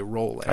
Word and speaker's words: Rowling: Rowling in the intro, but Trey informs me that it's J.K. Rowling. – I think. – So I'm rolling like bowling.